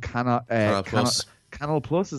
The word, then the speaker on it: Canal Plus: And there's a lot of money coming in there from Viventi, who own Canal Plus, is that how you say it? – Yeah, French station.